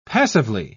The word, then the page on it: passively: British English